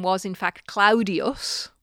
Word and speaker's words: Claudius: And I think the most famous incidence of this is the letter, or the letters, what we call a diphthong, two vowels being pronounced together, A-U, as in the name of the Emperor Claudius, which the correct pronunciation was Claudius. – Okay. – So A-U is ow.